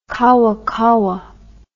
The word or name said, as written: kawakawa